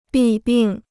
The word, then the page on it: 弊病: bì bìng